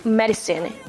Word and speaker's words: medicine: We pronounce it like medicine in American accent rather than instead of medicine. Okay, I, the second I here, we pronounce like uh, medicine, medicine, not medicine.